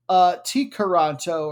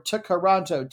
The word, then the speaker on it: Tkaronto: And unless the K is silent, they actually call it Tikaronto or Tikaronto, T-K-A-R-O-N-T-O in English.